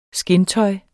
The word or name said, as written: skindtøj